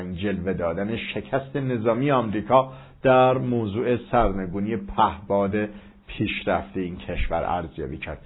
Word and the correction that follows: پهپاد: البته تلفظ صحیح پهپاد هست و نه پهباد که به عادت بعضا اشتباه میگوییم.